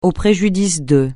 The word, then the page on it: au préjudice de: francais